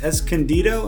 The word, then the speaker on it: Escondido: Today's Cocktail Party Podcast was inspired by Stone IPA, brewed in Escondido. Am I saying that right? – That sounds about right.